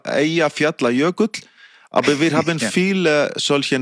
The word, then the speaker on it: Eyjafjallajökull: Also wir haben ihn, glaube ich, immer ausgesprochen mit Eyjafjallajökull. Aber jetzt möchte ich es einmal in Isländisch korrekt hören. Wie hieß dieser Vulkan? – Ja, es ist ein ganz einfacher Name. Es heißt einfach Eyjafjallajökull.